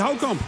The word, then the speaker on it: Houtkamp: En die Houtkamp, zeg ik het goed? – Ja, dat zeg je heel erg goed.